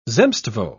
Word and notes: zemstvo: British English

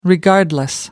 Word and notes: regardless: American English

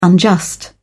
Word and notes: unjust: British English

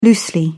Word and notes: loosely: British English

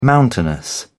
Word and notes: mountainous: British English

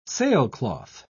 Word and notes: sailcloth: British English

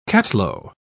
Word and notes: cattalo: British English

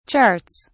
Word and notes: charts: British English

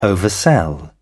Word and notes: oversell: British English

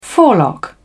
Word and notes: forelock: British English

